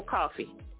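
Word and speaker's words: Coffie: April, the last name is spelled C-O-F, is in Frank, I-E, April Coffee.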